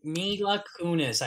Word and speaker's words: Mila Kunis: Mila Kunis, I believe is how you say it. Mila Kunis, yes. Not Mia Malkova.